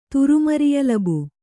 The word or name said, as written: ತುರುಮರಿಯಲಬು